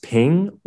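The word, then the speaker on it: XPeng: Brands like NIO and I think it's pronounced Peng, it's X-P-E-N-G, and Li Auto are taking market share.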